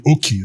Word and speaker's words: Okey: You know, my name is spelled O-K-E-Y. Even though it's called Oki in Nigeria, the white man or the American called me Oki, like key with an O in front, Oki.